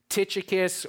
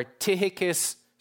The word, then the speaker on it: Tychicus: As to all my affairs, Tychicus or Tychicus, some pronounce it, our beloved brother and faithful servant and fellow bondservant in the Lord.